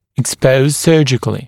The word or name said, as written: expose surgically